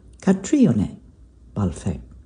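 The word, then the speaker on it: Caitríona Balfe: Catriona Balfe. Accent on the I there, I think.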